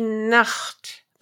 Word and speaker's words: Nacht: This word is also good to see why it's important not to pronounce the ch as a k. Die Nacht is the night and nackt is naked.